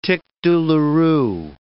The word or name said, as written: tic douloureux